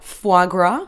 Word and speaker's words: foie gras: Is that how I say it right? Foie gras? – Yeah, you got it.